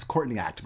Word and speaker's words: Courtney Act: That's how you say her name. It's Courtney Act, but she's Australian. Caught in the Act.